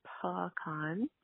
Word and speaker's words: pecan: I say pecan, um, with lots of emphasis on the con part. Pecan. The it's almost like a p apostrophe c a h n pecan, and um, I say praline, but I do have friends that say praline.